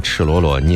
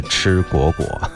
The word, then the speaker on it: 赤裸裸: 那上学的时候呢还把赤裸裸念成了吃果果，好有意思哈，这是过去的一些记忆。